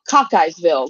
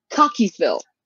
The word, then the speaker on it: Cockeysville: It looks like Cockeyesville. Like you got a lazy eye kind of thing. Yes, like it's a Cockeyesville, but it's actually pronounced Cockeysville.